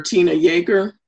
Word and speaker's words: Yeager: And under Tina Yeager? – Yes. – Okay. And her last name is spelled Y-E-A-G-E-R, just in case you didn't know.